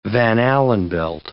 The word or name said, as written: Van Allen belt